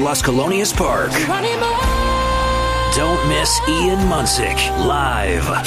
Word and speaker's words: Ian Munsick: Munsick. It's exactly like it sounds.